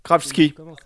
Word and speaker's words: Krawczyk: Ah non, mais là, attends, ça s'écrit K-R-A-W-C-Z-Y-K. Kravski. – Kravski. Kravski. Pardon.